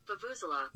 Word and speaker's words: vuvuzela: So you can practice it and then get close to the thing that we're all striving for today, which is vuvuzela.